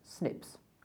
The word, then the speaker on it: SNPs: These are abbreviated to SNPs, which is actually pronounced SNPs.